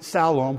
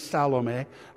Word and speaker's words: Salome: One is he pronounced it Salome, which is her name is Salome. I just know that because that's her name. That's how you pronounce it.